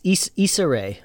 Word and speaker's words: Issa Rae: Issa Rae.